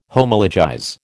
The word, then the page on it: Homologize: American English